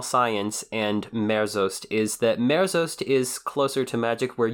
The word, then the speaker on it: merzost: And it is merost. Am I pronouncing that right? – The word is merzost.